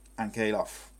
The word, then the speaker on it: Ankalaev: The Russian, I can't say his name, but Ankalaev.